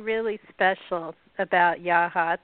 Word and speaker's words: Yachats: By the way, you did pronounce it correctly. At least thank you. – We pronounce it Yawhats.